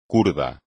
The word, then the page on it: kurdo: Spanish